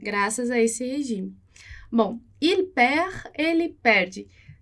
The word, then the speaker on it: il perd: Lembra lá que o D, o S, o T a gente não pronuncia no final de palavra. Então, isso vai acontecer também com il perd. Il perd. A gente não vai pronunciar o D.